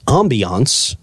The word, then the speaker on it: ambiance: Which one's the right way to say? – Ambiance. You said it right. – I'm going to make a note of that. A-H-H-H-H-H. – Ambiance. – Ambiance, right. Ambiance. – Not ambience? – No. – Not in this case.